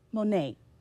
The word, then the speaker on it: Moné: Moné.